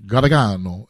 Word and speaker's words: Gargano: It's supposed to be, like, the old world says it, like, Gargano. Gargano is how you say it.